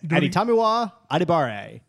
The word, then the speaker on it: Adetomiwa Adebawore: The Colts draft defensive lineman out of Northwestern, Aditamiwa Adibare. – We need a drum roll right there. – That's right. In order to make that pick. Aditamiwa Adibare.